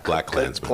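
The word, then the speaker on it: BlacKkKlansman: You just pronounce it Black Klansman or Black? – Black Klansman. – Sound like you have three K's in the middle of the thing, you know? – It's not for Mel Tillis.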